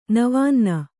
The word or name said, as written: ನವಾನ್ನ